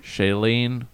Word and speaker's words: Shailene: Shailene.